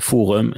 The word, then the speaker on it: forum: Samme forum, Det skulle jo blitt uttalt forum.